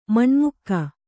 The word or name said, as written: ಮನೆಮಗ